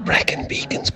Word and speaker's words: Brecon Beacons: It's pronounced Brecon Beacons, but don't tell anybody.